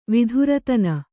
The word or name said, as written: ವಿಧುರತನ